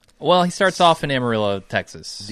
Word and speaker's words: Amarillo: So, Amarillo is how you say this.